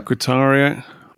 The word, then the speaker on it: Querétaro: Querétaro, was it? Is that how you pronounce it? – Yeah. – Querétaro.